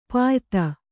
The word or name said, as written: ಪಾಯ್ತ